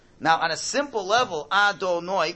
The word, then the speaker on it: Adonai: It is spelled this way, Yud Kei Vav Kei, but it's pronounced this way. Now, when you say that name, when you say Adonai, you have to be thinking of two things.